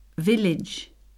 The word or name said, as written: village